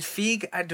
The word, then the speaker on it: Feig: The director, Paul, I think, is it Feig? Do I say that right? I don't even know. – That's how I've always heard it pronounced.